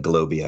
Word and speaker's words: GLOBE: I was proud of a personal triumph last week when the OECD responded to me on LinkedIn to confirm that we are not pronouncing the E in GLOBE for the Global Anti-Base Erosion Information Return. Every time someone said GLOBE, I would just think of Pee Wee Herman, and I couldn't shake that image, so I needed closure.